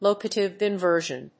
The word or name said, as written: locative inversion